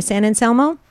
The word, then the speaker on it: San Anselmo: San Anselmo? – Yeah.